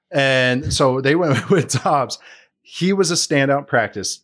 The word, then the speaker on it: Doubs: I always want to say Romeo Doubs, but apparently it's Dobbs, how you pronounce it. I've heard three different pronunciations for it. So I'm just. – I think it is Dobbs. They threw the U in there just to confuse everybody.